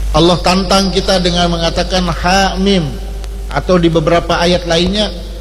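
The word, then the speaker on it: ha mim (حم): Jangan ha mim dibaca hem, hem. Yasin yes.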